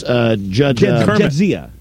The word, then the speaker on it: Jadzia: It's Jadzia. – Jadzia. – Jadzia. – Jadzia. Jadzia. – Jadzia. – Ooh. Ooh, yeah, she's got it too. – Jadzia.